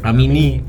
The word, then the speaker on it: Amini: Hussein Amini? Non ho studiato. – Credo l'accento finale. – Amini.